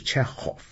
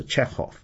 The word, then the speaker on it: Chekhov: 但其实中文这个翻译很准确，是契诃夫，这个喝呢其实是准确的掌握了原来俄文的发音，因为俄文里面契诃夫就是 Chekhov，那个 K 是不发音的，是不发音的，那个是个弱音，或者至少是 Chekhov，那是俄文的念法是比较准确的。